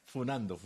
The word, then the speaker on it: funando: Y ahora no se dice cascando, ahora se dice funeando. – Usted, pero eso es youtuber. Un mensaje de 48 años, por favor. – Sí, funando, me aclaran.